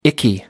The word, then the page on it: icky: American English